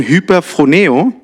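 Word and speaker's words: hyperphroneo (ὑπερφρονέω): Das andere Wort, das Überschätzen, wird im Griechischen so ausgesprochen, hyperphroneo.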